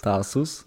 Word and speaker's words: Tarsus: Tarsus.